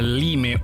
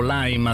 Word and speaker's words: Lime: Il Foglio, le nostre anime politiche spiegate da una bicicletta, perché la Lime o Lime, a seconda di come vogliamo pronunciarli, un'azienda è diventata uno stress test romantico sui tabù culturali di destra e sinistra.